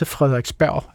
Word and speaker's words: Frederiksberg: Men så siger han også Frederiksberg. Altså, jeg synes faktisk, han udtaler æret og G'et i Frederiksberg.